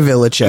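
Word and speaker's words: Villechaize: Well, first of all, isn't he Hervé Villachez? – Yes. Hervé Villachez. I mean, yeah, that's one thing. – A proud man of Mexican heritage, you would think you would pronounce that right. – That last syllable.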